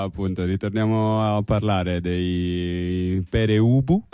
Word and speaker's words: Pere Ubu: ci arriva un messaggio che dice: il vero punk pronuncia Pere Ubu come è scritto.